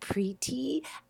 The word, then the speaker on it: Priti: And the coolest thing about Priti is actually if you say it, it kind of ends in an E. So it sounds like Priti.